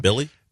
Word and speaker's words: Billy: But it stars Millie Magnuson, I think is how you say his name. – Billy Magnuson. Yeah. I promise I can say it right.